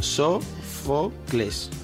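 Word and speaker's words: Sófocles: Sofloques. Sofloques. – Sí. Sofloques. Sofloques. ¿Cómo se dice? Sofocles. Flo no. No es Flo.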